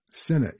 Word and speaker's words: Sinne: That is S-I-N-N-E, last name E-E-G, pronounced E, Sina E, from the album by the San Gabriel 7 called Under the Stars.